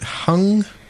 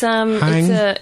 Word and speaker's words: Hang: you pronounced it hung? – Yeah, it's... hang? Hang, I would say, is easy for everyone because it's spelt like that.